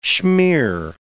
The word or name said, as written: schmeer